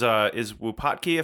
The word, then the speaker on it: Wupatki: And I think is Wupatki, if I'm pronouncing that right.